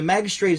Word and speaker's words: magistrate: In modern uses, a magistrate is a judge or lawyer who hears... Yeah, but I want to know how to pronounce it. Here we go. – Magistrate. – Like a J. Magistrate. Make an effort to settle the matter on the way.